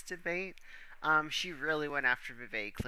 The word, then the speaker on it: Vivek: Vivek rhymes with fake. Or like he says, Vivek rhymes with cake.